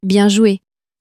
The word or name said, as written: bien joue